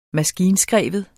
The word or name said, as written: maskinskreven